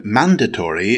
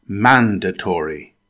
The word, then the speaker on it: mandatory: Now, the word mandatory is an adjective. It's pronounced with four syllables. Stress the first syllable. It's pronounced mandatory. Mandatory.